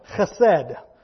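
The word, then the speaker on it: chesed (חסד): It's spelled chesed, but it's pronounced chesed. It's a little phlegmy word.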